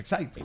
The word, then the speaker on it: Exide: Se escribe Exide, se pronuncia Excite, decía el comercial de las baterías. – Sí, señor. – Así se escribe Exide, se pronuncia Excite.